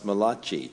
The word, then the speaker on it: Malachi: Mary Lodadio was telling me that it should be pronounced Malachi.